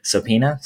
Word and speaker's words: subpoena: Subpoena. – Yeah, I don't pronounce the B.